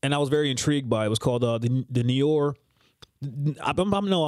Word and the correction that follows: Noir: The New York. N-O-I-R. How is that pronounced? Because I'm fucking this up bad. – N-O-I-R. Noir.